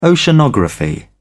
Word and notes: oceanography: British English

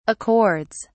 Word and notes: accords: British English

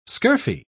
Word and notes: scurfy: British English

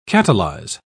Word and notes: catalyse: British English

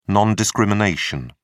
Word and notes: non-discrimination: British English

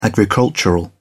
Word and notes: agricultural: British English

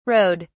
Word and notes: rowed: British English

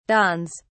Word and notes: dawns: British English

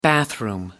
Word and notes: bathroom: American English

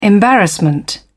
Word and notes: embarrassment: British English

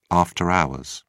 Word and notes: after hours: British English

